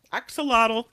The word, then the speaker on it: axolotl: Axolotl. Is that how you say it? – Axolotl. Yep.